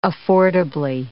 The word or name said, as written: affordably